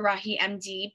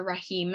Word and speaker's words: Rahi: People always think my name is Dr. Rahim. And I'm like, no, it's Rahi. Rahi MD.